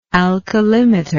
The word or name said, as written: alkalimeter